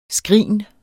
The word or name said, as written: skrin